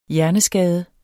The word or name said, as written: hjerneskade